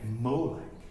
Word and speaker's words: Molech: Which, if you put the vowels of the word shame into the consonants of the word Melech or MLK, you get Molech. And Molech is an idol. And it's an idol whose name is pronounced by mispronouncing ruler with the vowels of shame.